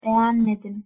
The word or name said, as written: begenmedim